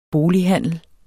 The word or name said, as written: bolighandel